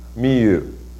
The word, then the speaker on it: Miyu: Shinketsu. – So, Shinketsu, yeah. He's reading the literal Chinese reading for how you would normally pronounce these words. But this is how her name is pronounced. Miyu.